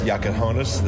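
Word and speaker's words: Jakučionis: Yeah, I mean, he's taken on a little more of a secondary role with Kasparis, Jakachonis. That's how you say it.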